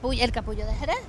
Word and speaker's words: El Capullo de Jerez: El capullo de Jerez.